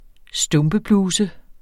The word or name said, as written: stumpebluse